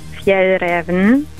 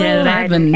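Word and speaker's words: Fjällräven: Fjell Raven. – Also Fjell Raven.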